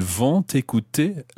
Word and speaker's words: vont écouter: Ils vont écouter la radio demain. Why am I pronouncing the T of ils vont? – Because écouter starts with a vowel.